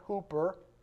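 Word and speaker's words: hooper (ὑπέρ): Now to him who is able to do far more abundantly beyond, that Greek word for beyond, hooper, kind of rhymes with super, doesn't it?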